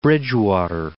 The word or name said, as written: Bridgewater